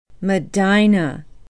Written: medina

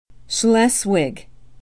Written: Schleswig

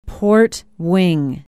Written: port wing

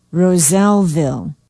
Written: rozellville

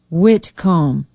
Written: whitcomb

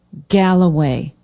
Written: Galloway

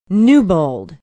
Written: newbold

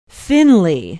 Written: Finley

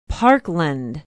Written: parkland

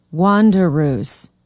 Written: wanderoos

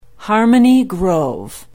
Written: harmony grove

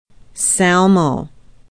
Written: Salmo

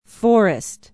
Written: forest

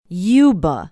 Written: yuba

